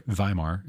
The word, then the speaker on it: Weimar: I'm sorry, Weimar is how it's pronounced, but it's spelled Weimar.